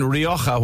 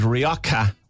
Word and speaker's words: Rioja: And the Spanish wine, Rioja, why do pub staffs pronounce it Rioja when it's Spanish? It should be Rioja con la Jota.